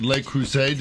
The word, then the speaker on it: Le Creuset: Homan Kokabi is with Le Creuset.